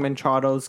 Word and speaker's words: Machado: How's it pronounced? – Machado. – Machado. – There's no N in there. There's no I in there. It's not Minchado.